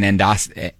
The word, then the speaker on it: endoscopy: We got a text from Laura who said, I work in endoscopy.